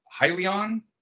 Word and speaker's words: Hyzon: So I did notice that you were entering into a partnership with Hyzon. I think that's the way it's pronounced. – Yep.